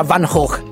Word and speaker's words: Van Gogh: Premettiamo che ci siamo informati sulla corretta pronuncia del cognome e abbiamo scoperto che si pronuncia Van Gogh, visto che la G in olandese è tipo una H.